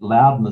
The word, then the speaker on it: Loudon: Loudon is spelled L-O-U-D-O-N.